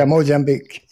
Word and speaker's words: Mozambique: Yeah, Mozambique, yes.